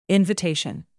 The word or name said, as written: invitation